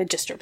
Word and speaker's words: Magistra: Which would actually be Magistra, presumably, for the Latin pronunciation.